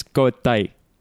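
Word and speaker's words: cómo estás: My example is everyone knows como estás. In Chile, it's cotai.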